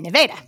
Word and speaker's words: Nevada: I have no – And the other one, just over the border in Missouri, Nevada, which is spelt like Nevada. – But you have to say Nevada.